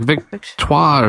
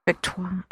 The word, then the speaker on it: Victoire: She pronounces it in weird, with a French, like Victoire or something. – Victoire. – Right. I mean, I did not read it that way. It's like, Victory, cool.